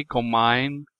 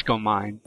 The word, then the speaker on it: Gomine: I'm going to say Gomine. – I think that's right. – Mm-hmm. – Yes. Yeah. Okay. It just, for some reason, my English brain makes me want to say Gomine, but it's Gomine.